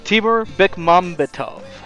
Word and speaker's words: Timur Bekmambetov: Timur Bekmambetov. – What?! You did it!